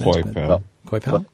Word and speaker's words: Coipel: Started off huge with amazing, amazing artwork by Olivier Coipel or Coipel. I'm not sure how you'd pronounce it. – Coipel. Just make it sound as French as you can.